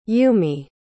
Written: yumi

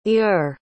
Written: ur

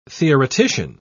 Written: theoretician

